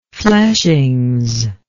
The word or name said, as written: fleshings